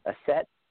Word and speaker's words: Aset: Do I want to do Isis a set? A set is actually the Egyptian pronunciation. Isis is the Greek pronunciation of a set,